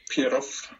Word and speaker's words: Pierroff: Pieroff.